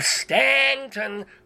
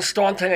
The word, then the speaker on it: Staunton: You put the U in Staunton and you don't pronounce it, and you've got the last name McSweeney and it doesn't have a third E in it.